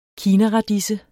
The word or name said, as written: kinaradise